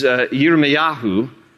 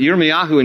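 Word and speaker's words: Yirmiyahu (ירמיהו): Jeremiah's name in the Hebrew is Yirmiyahu. That's how you pronounce it. Yirmiyahu in Hebrew translates God throws.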